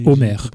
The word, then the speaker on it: Omer: why not make it simple and call it Homer, O-M-E-R? Because in French you don't pronounce the H anyway.